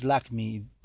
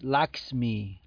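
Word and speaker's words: Lakshmi: No es Lakmi, tonto, es Laxmi, Laxmi, te falta la X.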